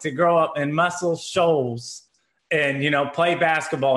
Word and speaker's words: Muscle Shoals: Muscle Shoals.